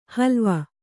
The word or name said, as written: ಹಲುವ